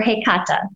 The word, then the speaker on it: Hecate: So I strongly align with Hecate, or Hecata. That's how she actually pronounces it for me. So I know it's not necessarily the mainstream pronunciation of Hecate, but that would be my goddess I align with the most.